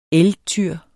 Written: elgtyr

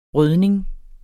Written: rødning

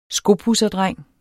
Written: skopudserdreng

